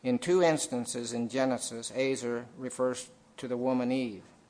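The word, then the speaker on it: ezer: This word helper in the Hebrew, it's E Z E R, Azer, I think is how it's said.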